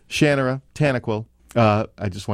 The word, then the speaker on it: Shannara: You know, I say Shannara.